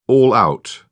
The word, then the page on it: all-out: British English